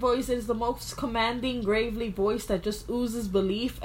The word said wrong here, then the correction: gravelly: Commanding and gravely. That does not give me belief gravelly.